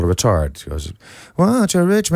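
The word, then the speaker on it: ritard: And if you slow that down, it's called a retard. And that's not spelled like a. That's like the Italian, it's a R-I-T-A-R-D.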